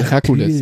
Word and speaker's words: Hercule: Und so in die Richtung, ja, Hercule Poirot, genau, mit H, ach, also Hercules.